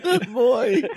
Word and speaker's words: Nimoy: Well, look at the amount of makeup on Nimoy. Is that how you pronounce it? – Nimoy!